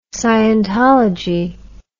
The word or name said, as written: scientology